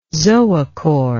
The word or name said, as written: zoochore